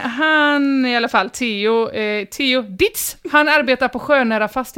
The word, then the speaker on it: Dietz: Du men hur uttalas det då? – Dietz stavas det. – Oj. – Eller D-I-E-T-Z.